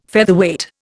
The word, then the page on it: Featherweight: Indian